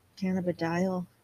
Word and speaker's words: cannabidiol: Cannabidiol. – Yeah. – That's cannabidiol.